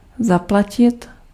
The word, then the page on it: payer: France; [pe.je]